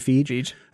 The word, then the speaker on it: Feig: Feig.